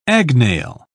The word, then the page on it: agnail: British English